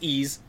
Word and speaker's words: Ys: It's actually pronounced Ys, like easel without the L.